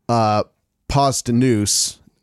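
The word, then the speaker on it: Posdnuos: And they, the surviving members, Paz de Nuce, I'm saying that right, right? – That's correct.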